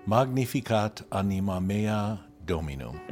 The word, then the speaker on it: Magnificat anima mea Dominum: And I am not great with Latin, but it's something to the extent of Magnificat anima mea Dominum. – Oh, wonderful. No, your pronunciation is fine. Magnificat anima mea Dominum.